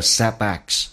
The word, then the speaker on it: setbacks: to those who are also thankful for the setbacks. Setback 的 t 可以不发音，在美式发音两个辅音在一起，前面一个辅音不用发，气流阻塞， setbacks, 挫折。